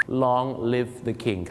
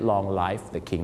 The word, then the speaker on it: Long live the king: Long live the king ครับผมขอให้ทรงพระเจริญไม่ใช่ Long life the king